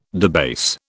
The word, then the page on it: debase: British English